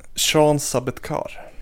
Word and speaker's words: Sean Sabetkar: Som undrar hur nära, och du får hjälpa mig med uttalet här, men Sean Sabbe... Sabbe jag, vet, jag vet ju 100% vem det är, men jag har aldrig lyckats uttala hans namn. Hjälp mig. Eh. Sean Sabetkar.